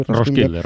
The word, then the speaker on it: Roskilde: cómo se pronuncia en danés, escrito así. – Roskilde, Roskilde, está bien.